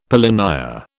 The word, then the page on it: polynya: British English